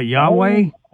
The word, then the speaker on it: Yahweh: You're talking about Yahweh? – That's what I mean. That's the way we say it is Gentiles or whatever. But it's spelled YHWH, no vowels.